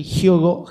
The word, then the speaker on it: Hyogo: A ver, Amagasaki, Hyogo, Japón. Ahí está, mejor pronunciado.